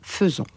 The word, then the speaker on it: faisons: alors que le AI se prononce « e ». Hein, faisons.